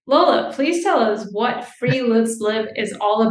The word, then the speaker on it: friluftsliv: And it's called a friluftsliv. That's spelled friluftsliv.